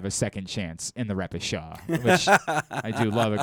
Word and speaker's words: repechage: Repechage.